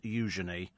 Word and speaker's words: Eugenie: It's pronounced Eugenie.